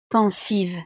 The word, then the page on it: pensive: frances